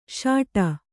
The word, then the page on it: ಸಟ್ಟಾ: kannada